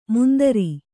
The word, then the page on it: ಮುಂದರಿ: kannada